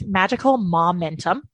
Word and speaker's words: Magical Momentum: So it's Magical Momentum. It's spelled momentum, but it's emphasis on the mom.